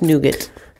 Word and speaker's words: nougat: Is it nougat or nougat? – Nougat. – Let's ask Siri. – I think it's nougat. – Nougat? – Nougat. – Hey Siri, how do you pronounce the word that's spelled N-O-U-G-A-T? Nougat. It is nougat.